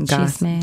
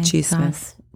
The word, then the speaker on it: chisme: That's how you say it? – Chisme. – Chisme.